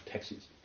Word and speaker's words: taxis: So taxis is spelled in Greek T-A-X-I-S, like taxis. But it's not a taxi. We're not talking about a taxi. It's a taxis.